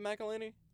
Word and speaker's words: McElhinney: Is it McElhinney? – Yeah.